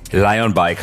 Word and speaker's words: Lion Bike: Lion Bike heißt das Unternehmen richtig ausgesprochen, also wie der Löwe, richtig? – Genau, Lion Bikes, ja.